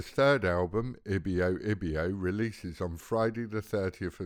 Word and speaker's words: Ibio Ibio: Ibio Ibio.